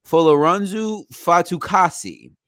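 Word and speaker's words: Folorunso Fatukasi: And 94 is, let me make sure I'm pronouncing his name right, Folorunsu Fatukasi. – Right. Good job.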